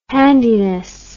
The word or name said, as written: handiness